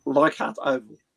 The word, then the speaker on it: Leichhardt: Leichhardt Oval.